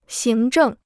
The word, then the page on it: 行政: xíng zhèng